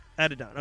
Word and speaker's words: Adidon: Like, he's like, after Story of Addis? Addis? How do you say it? Adonis? – Adidon, okay.